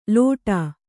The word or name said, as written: ಲೋಟ